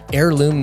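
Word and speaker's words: Airloom: Heirloom Media, that's spelled A-I-R.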